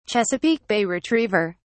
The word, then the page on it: chesapeake bay retriever: British English